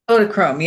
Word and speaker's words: Kodachrome: Kodachrome, am I saying that correctly? – Kodachrome, yeah.